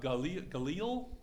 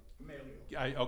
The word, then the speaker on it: Gamaliel: Paul studied under Galil. I never get his name right. – Gamaliel.